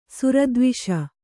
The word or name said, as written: ಸುರದ್ವಿಷ